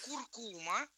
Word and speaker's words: куркума: Ну, во-первых, куркума, куркума.